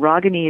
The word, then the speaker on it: Ragini: And Ragani is spelled R-A-G-I-N-I. And Michaels, M-I-C-H-A-E-L-S, as in Sam.